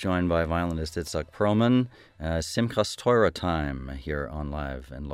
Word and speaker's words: Simchas Torah: I'm not sure I'm going to pronounce this right. Simchas Torah Time? – Simchas Torah Time. – There you go.